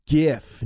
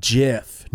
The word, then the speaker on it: GIF: It's pronounced GIF, not JIF, No Fear.